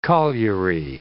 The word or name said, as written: colliery